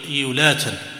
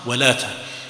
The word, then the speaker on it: ولاتا: يسمونها إيولاتا، ينطقونها هكذا إيولاتا، واسمها ولاتا.